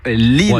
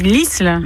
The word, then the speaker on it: l'Isle: Dit-on l'Isle ou l'île, ouais, Mylène? – Alors nous, on dit l'Isle. – L'Isle, donc ouais. Le S se prononce.